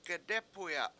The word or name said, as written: gedEpuya7